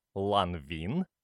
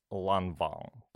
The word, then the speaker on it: Lanvin: Аналогичных слов много, например, другой модный бренд, женщиной, кстати, основанный, многие произносят, как пишется, «Lanvin», на самом деле правильно читать «Lanvin», по той же самой причине.